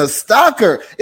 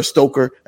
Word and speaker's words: Stocker: Is it Stocker or Stoker? Am I saying that wrong? I never really knew. – You were good the first time. I always get Stoker because of the American accent, but either one works.